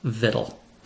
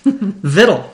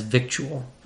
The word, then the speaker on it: victual: but according to every dictionary we checked, including Merriam Webster, American Heritage, and even the venerable Oxford English Dictionary, the word is properly pronounced vittle. vittle, seriously. I've known this word for decades now, and I have always pronounced it in my head as victual